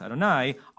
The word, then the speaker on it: Adonai: You, Adonai, Yud Hei Vav Hei, which is pronounced Adonai, are our El.